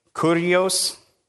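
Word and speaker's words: Kurios (κύριος): Kurios. It sounds like curious. Kurios. That's what it is. It sounds like curious, but it's not.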